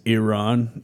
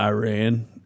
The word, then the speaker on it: Iran: So like the first thing that the Iran guy said, well, he like grilled him for, he's like, you pronounced the name of our country wrong. It's Iran, not Iran.